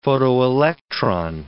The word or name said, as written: photoelectron